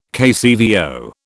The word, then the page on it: KCVO: British English